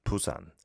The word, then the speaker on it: Busan: Busan, Busan is how you say it in Korean, right? Busan. It's not Busan or anything like that.